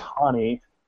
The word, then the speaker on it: Hani: we actually talked about this before we went on the air, but it is pronounced Hani. Like H-A and then knee, like Festus Azili's knee injury problem.